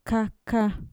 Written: kaka

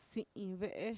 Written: si'inve'e

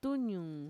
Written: tuñuun

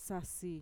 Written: sasi

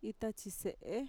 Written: itatxise'e

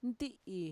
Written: nti'i

